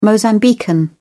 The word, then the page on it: mozambican: British English